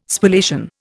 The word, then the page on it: Spallation: Indian